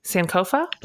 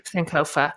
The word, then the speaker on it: Sankofa: I want to say it correctly. Sankofa? – That's it. – Mm-hmm. Sankofa.